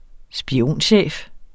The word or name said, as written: SPIONCHEF